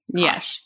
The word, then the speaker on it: Kasch: Kasch.